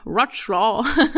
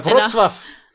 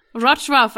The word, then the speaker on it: Wrocław: I'll start with a topic that I actually connect deeply to skepticism, because when I went to my first skeptical conference in Wrocław. – Wrocław. – Wrocław.